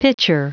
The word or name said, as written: pitcher